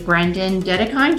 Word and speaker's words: Brendon Dedekind: Today, I have with me Brendon Dedekind.